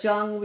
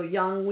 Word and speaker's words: Jung: We go Jung, we go Young, we go whatever. – Jung.